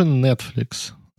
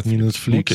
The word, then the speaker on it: нетфликс: Конечно же, нетфликс. Вот, не нетфликс. Okay. У тебя всегда ударение на... Первый слог, вот, ну, я тут профессионал, я уже который неделю занимаюсь с преподавателем английского ровно вот на акцентах и произношениях.